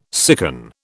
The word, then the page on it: sicken: British English